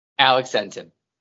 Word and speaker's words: Alex Entin: Alex Entin.